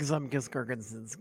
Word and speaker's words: Zemgus Girgensons: Like I said, big Zemgus Girgensons guy.